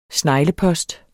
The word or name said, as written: sneglepost